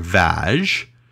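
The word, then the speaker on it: Vág: Nadazny's wedding gift to Bathory was his household, Castle of Cheta, situated in the Little Carpathians near Vaj. This could be pronounced Vaj or Vaj. I'm going with Vaj. Usually. – How is it spelled? – V-A-G. – Oh. – But like with an accent.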